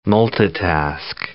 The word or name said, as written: multitask